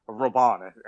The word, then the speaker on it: Robben: Arjen Robben is a player um, I think of Roban, actually, is how it's pronounced.